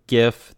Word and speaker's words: GIF: You know they had video, GIF. That's how you pronounce it.